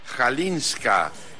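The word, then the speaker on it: Chwalińska: Maya Jalinska. Ya me puse a investigar cómo se pronuncia el apellido de esta niña, que en el inicio es una CHW, eso según ella se pronuncia como J, vamos a darlo por bueno, que sorprende y derrota a la tenista rusa Diana Schneider 7-6-6-4.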